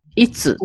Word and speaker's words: itsu: いつって、とか、 ITSU と書いて、いつっていうお店なんですけど、ああいうね、つっていう TSU とかがね、すごく日本っぽいんだと思いますよ、多分。